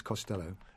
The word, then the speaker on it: Costello: No, it's Costello.